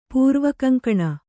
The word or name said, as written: ಪೂರ್ವಕಂಕಣ